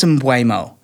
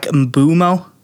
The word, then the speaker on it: Mbeumo: Is it Mbeumo? Did we go with Mbeumo? – Everyone says Mbeumo, but his name is EU, not UE. So I think it might be like Mbeumo. – Mbeumo.